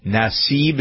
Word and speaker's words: Nassib: And perhaps we'll have the story of Carl Nassib.